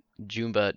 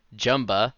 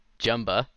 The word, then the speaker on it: Jumba: Instead of saying Jumba Jukuba or Jumba Jukiba, it's Jumba. Basically, think of Jumbo, but you're replacing the O with an A. It's Jumba and Jukiba, just like he says in the movie.